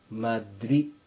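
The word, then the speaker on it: Madrid: マドリ、デで終わっています。世界の中でも有名な都市ですから、普通、マドリッドと認識されていますが、 R の後ろに、 I にアセントがありますので、そして、 D は発音しません。それですから、マドリ、マドリになります。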